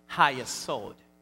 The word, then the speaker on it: hayesod: That word is called hayesod. It's spelled H-A-Y-E-S-O-D.